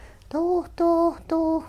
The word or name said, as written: doo doo doo